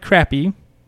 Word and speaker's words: crappie: His final meal was fried crappie. You said that's how it's pronounced.